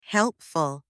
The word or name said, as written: helpful